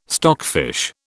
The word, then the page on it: stockfish: British English